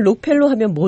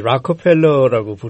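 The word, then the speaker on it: Rockefeller: 미국 사람들은 록펠러 하면 못 알아듣더라고요. – 그렇죠. 이 락펠러라고 부르죠.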